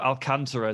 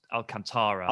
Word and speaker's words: Alcantara: No, Thiago Alcantara is a good example here. – Yeah, because he's more commonly spoken as Alcantara.